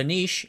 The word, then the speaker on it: Niš: The Niš in A-Niš rhymes with leash, refers to a city in Serbia, one of the countries in the Socialist Federal Republic of Yugoslavia.